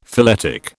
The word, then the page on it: phyletic: British English